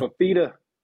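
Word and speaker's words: Fifita: Gotta talk about how do you say his last name? En Nafita? – Fafita.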